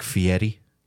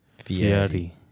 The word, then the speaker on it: Fieri: The way that I always explain how to pronounce it correctly is it's almost like the R becomes a soft D, like Fieri. – Fieri. – Like Fieri. Fieri. Fieri. – That's Fieri.